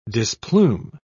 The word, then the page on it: displume: British English